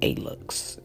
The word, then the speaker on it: Alux: It's called Alux. It's spelled A-L-U-X. Alux.